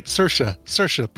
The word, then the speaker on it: Saoirse: Saoirse. Rhymes with inertia.